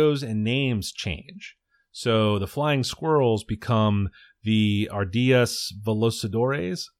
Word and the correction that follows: Ardillas voladoras: Oh no, did I say that right? Ardillas voladoras, voladoras, Ardillas voladoras, which is just Spanish for flying squirrels.